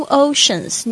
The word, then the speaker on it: oceans: New oceans， new 这个就是新的， oceans 特别注意一下这个 c 的发音，在这边呢是发 sh 的声音。